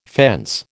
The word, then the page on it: fence: American English